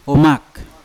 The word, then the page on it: omak: Yawa